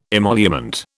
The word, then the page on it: emolument: British English